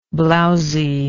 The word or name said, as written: blousily